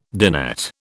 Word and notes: dinette: British English

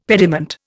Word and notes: pediment: Indian

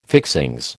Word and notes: fixings: American English

